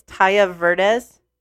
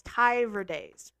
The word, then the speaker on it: Ty Verdes: A-O-K. – Fantastic. – I don't know how you pronounce the guy's name. I think it's Taya Verdes. – It's Ty Verdes.